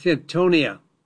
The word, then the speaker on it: Hintonia: The plant is known as Hintonia.